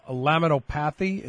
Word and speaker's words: laminopathy: And I thought progeria was considered something called a laminopathy. Is that the proper pronunciation? – Laminopathy. – Laminopathy. Thank you.